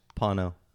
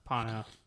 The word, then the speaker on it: Pono: I want to pronounce it like Bono, so it's Pono. – Pono.